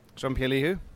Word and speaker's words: Jean-Pierre Lihou: Jean-Pierre Lihou. – We'll try and say that with an Irish accent. That's Jean-Pierre Lihou. Did I pronounce that correctly? – That's it, Channel Islands.